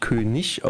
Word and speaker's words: König: Also es wird tatsächlich König mhm. – ausgesprochen, mhm. das ist mir auch neu. IG am Ende immer mit CH.